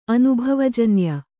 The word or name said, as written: ಅನುಭವಜನ್ಯ